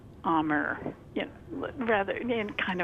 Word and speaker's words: Amur: And they pronounce it Amur, like A-H-M-O-O-R.